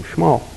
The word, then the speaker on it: Shmuel: That's how you actually say his name, Shmuel. And not Shmuel, Shmuel. And Shmuel, Samuel, Shmuel.